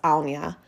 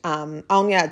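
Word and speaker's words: Áine: And it's so funny because yesterday in one of my journeys, I was connecting with the goddess Aunia. And Aunia, it's spelled A-I-N-E.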